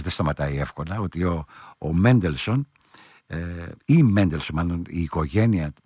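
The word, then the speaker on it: Mendelssohn: όπως προφέρεται από τους Σάξονες, Μέντελσον.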